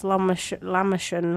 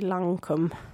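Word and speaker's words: Lancôme: And I tried to say that they were pronounced Lamashan, like the Turkish pizza, but it's actually Lancum. Is that correct? – Lancum, yeah, that's the one.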